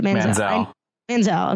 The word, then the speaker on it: Manziel: How do you pronounce that? – Manziel.